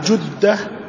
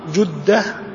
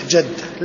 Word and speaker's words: جدة: طبعا جدة جدة هذا الصحيح وليس بالفتح، لأن ينطقونها بالفتح جدة، لا هي جدة.